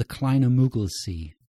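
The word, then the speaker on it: Kleiner Müggelsee: Oh, the Kleine Mugelsee. – Beautiful. – Was that good? Yeah. – Near enough. Yeah. Z, but never mind.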